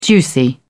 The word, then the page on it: juicy: British English